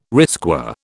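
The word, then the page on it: risqué: British English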